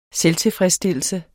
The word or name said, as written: SELVTILFREDSSTILLELSE